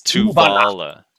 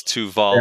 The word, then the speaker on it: Tuvala: Um, Alina Maria says, or Elena Maria says, every time says Tavula, a little piece of me dies. I, I do need to apologize for that. It's it's Tuvala. – It's Tuvala.